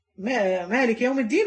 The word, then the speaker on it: مالك: فيبقى عندي ملك مالك يوم الدين وعندي ملك، يبقى الملك هي اللي مكتوبه في المصحف، لكن احنا بننطقها ايه او نقولها ايه؟ القراءه المشهوره مالك يوم الدين.